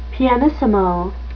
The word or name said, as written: pianissimo